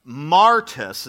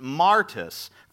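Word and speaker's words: martus (μάρτυς): Now, the Greek word for witness is martus, is how we would pronounce it, martus, from which we get the word what?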